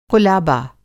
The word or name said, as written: क़ुलाबा